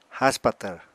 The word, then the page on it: haspatvr: apatani